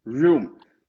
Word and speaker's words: room: Room. Mas lá atrás você falou home. Home. E a gente não fala com esse som de R. Esse é room.